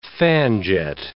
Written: fan-jet

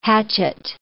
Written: hatchet

